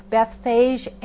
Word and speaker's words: Bethphage: Bethphage, that's pronounced with an F, Bethphage and Bethany on the hill called the Mount of Olives.